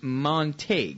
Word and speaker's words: Montague: The jailhouse escapades, some of which date to 2006, according to authorities, have rocked Montague, pronounced Montague. Yeah, I had that right the first time.